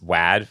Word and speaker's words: WAD: Yes, it is pronounced WAD for this, for purposes of rhyming with Mad Money.